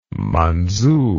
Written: Manzu

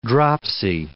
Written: dropsy

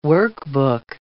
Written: workbook

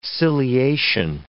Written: ciliation

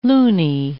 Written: loony

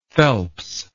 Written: Phelps

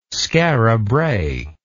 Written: skara brae

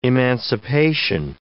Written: emancipation

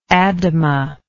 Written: Adama

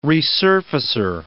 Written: resurfacer

